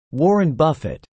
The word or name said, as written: Warren Buffett